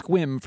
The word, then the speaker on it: Sequim: That's Squim for people. Chris does say it correctly.